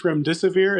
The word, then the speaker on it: remdesivir: I think it's remdesivir.